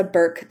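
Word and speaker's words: Burk: If you search Melissa Burke DeLang, and Burke is spelled without an E, B-U-R-K, Melissa Burke DeLang, you'll find my personal pages and my professional pages.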